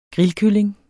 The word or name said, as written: grillkylling